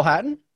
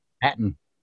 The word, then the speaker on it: Hatton: I want to say Hatton, but I just want you to pronounce that last name for me one more time. – Terrell Hatton? – Hatton. – Hatton. – There you go.